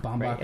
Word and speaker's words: Baumbach: Baumbach or Baumbach. Something. Yeah, Baumbach.